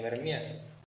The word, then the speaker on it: Vermeer: Se pronuncia Vermeer. O prácticamente así, vaya.